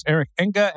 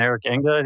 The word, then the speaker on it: Eric Enge: It's Eric Enga and it's Proficient. – Proficient it is and Eric Enga it is.